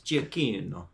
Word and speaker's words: Giacchino: Giacchino, I think, is how it's pronounced in the proper Italian, is Giacchino.